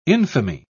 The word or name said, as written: infamy